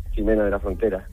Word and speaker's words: Jimena de la Frontera: Es un pipiolo maravilloso que llega desde Jimena de la Frontera. Lo he dicho bien, ¿verdad? En Cádiz. – Sí, Jimena de la Frontera, en Cádiz.